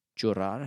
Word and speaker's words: llorar: L-L-O-R-A-R. – Llorar. So it would look like llorar. Gringos are not going to know how to pronounce that, so, but it's llorar. – Llorar, yes. If it's double L, we pronounce it yo. – Kind of like a J.